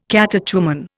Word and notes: catechumen: Indian

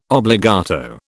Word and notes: obbligato: British English

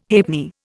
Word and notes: halfpenny: Indian

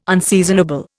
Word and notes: unseasonable: Indian

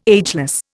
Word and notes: ageless: Indian

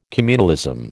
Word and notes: communalism: American English

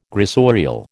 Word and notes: gressorial: American English

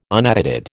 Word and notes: unedited: American English

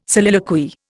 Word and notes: soliloquy: Indian

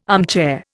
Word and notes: armchair: Indian

